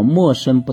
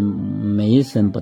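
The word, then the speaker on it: 没身: 莫生不殆，塞其兑，闭其门，终身不寝；开其兑，济其事，终身不救。戒小曰明，守柔曰强。用其光，复归其明。未生阳，是为细长。这一章啊，主要是有两个字是多音字的，那个叫莫生不殆啊，不能念成没生不殆啊。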